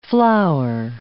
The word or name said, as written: flour